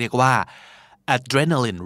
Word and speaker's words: adrenaline: adrenaline adrenaline